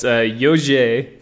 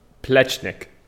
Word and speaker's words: Jože Plečnik: What, how would you say that? – It's Jože. – Yeah. Oh, yeah, right, yeah. Jože. – Plečnik. – Plečnik. That makes that sense a lot better.